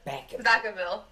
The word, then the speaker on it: Vacaville: How do you say it? – Vacaville. – Okay. – Vacaville. – Vacaville. – Oh, okay.